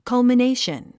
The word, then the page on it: culmination: kʌ`lmənéiʃən; カゥミネーション